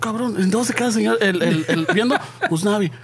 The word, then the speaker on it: Usnavi: Usnavi. Usnavi. Sí, Usnavi.